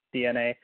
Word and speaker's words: DeNA: There's a company called DNA, and spelled like D-E-N-A, but it's pronounced DNA, and they have partnered with Nintendo.